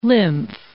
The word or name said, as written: lymph